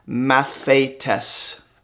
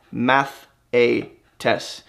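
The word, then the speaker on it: mathetes (μαθητής): Okay, the word disciple in the Greek, as it's used in the New Testament, in the English is pronounced mathetes, mathetes.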